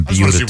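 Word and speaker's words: Buda: And we were learning how to pronounce Beulah.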